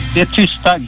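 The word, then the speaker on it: Det tysta: Detista Gold.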